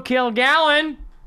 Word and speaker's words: Kilgallon: Am I saying that right? Joe? Do you have a... – Yeah, you got it right. I mean, you're really emphasizing both syllables. Well, there's three syllables. But you're really hitting the Kilgallen. – Gallen. It's really one word, Kilgallen. But I've always kind of emphasized the kill.